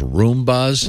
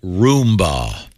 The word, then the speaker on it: Roomba: I am saying, however, I have one of those Roombas. – Mm-hmm. – Yeah. – How did you just pronounce that? – Roomba. – That's how it's pronounced.